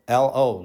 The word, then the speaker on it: lo: And in Hebrew, it's the word lo, L-O, lo, like pronounced that way, lo.